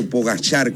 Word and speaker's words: Pogačar: Pogacar o Pogachar, como se le pronuncia, que es loeno, con Vingegaard, que es danés, es como que hoy día Alcaraz-Sinner.